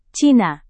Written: cina

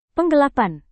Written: penggelapan

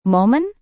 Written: momen

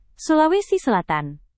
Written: sulawesi selatan

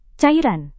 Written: cairan

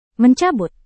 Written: mencabut

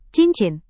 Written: cincin

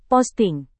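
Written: ppn

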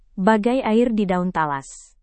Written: bagai air di daun talas

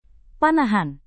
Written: panahan

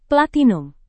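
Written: pmi

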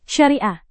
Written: syariah

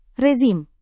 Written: rezim

